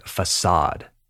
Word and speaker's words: facade: This next one is more of a pronunciation thing. And I'm talking about the word facade, spelled F A C A D E, fachada in Spanish. Facade is how we say it.